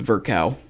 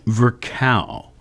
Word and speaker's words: Virchow: Rudolf, how are they saying that's pronounced? Virchow? – The thing that I saw said Virchow. – Virchow.